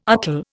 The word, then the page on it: artel: Indian